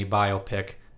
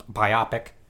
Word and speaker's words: biopic: The Dick Cheney biopic. And by the way, it's for everyone on the radio who mispronounced it. Biopic. Biopic. Biographical picture.